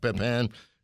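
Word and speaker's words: Pépin: And now we've got the co-founder and executive director of the Jacques Pepin. Am I saying it right? – Yes, Pepin.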